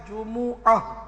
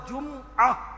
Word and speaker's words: جمعہ: تو عربی تلفظ قرآنی تلفظ اس کا ہے جمعہ، میم پر پیش ہے، لیکن اگر میم کو ساکن پڑھا جمعہ پڑھا جب بھی درست بنا جائے